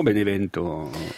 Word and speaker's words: Benevento: On arrive à Bénévent ou Benevento, selon si on prononce... – En français, Bénévent, Benevento.